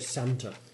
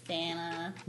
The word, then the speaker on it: Santa: You know the thing that annoys me on the radio and everything is how Americans say Santa. – Santa. – They miss out the T and it torments me so. – Santa. – I know. – Pecan. – You do it too. Yeah. Pecan with 97 A's. – Santa. – Santa. It's got a T. Say the T.